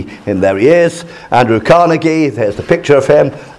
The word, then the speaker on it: Carnegie: Today we're going to look at Andrew Carnegie, and I am informed on from a reliable source that is the correct pronunciation, Carnegie. Most people say Carnegie, but apparently we're not supposed to.